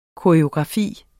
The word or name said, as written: koreografi